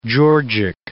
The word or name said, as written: georgic